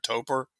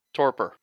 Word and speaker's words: torpor: But in this game, you can go into I can't pronounce the word, T-U-P-O-R, a topor? – Torpor.